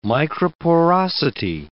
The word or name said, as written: microporosity